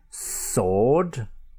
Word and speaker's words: sword: Other words in which you find W unpronounced can be sword, so S W O R D, but you have got that S O sword.